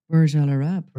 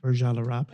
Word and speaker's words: Burj Al Arab: Burj Al Arab. – Burj Al Arab.